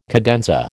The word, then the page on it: cadenza: American English